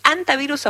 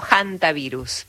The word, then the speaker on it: hantavirus: ¿Es antivirus o hantavirus, para decirlo bien? – Es con H, uno puede pronunciarlo de las dos maneras.